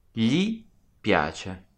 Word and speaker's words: gli piace: Gli piace. – And that's spelled G-L-I, gli piace.